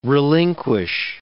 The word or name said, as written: relinquish